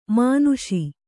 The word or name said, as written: ಮಾನುಷ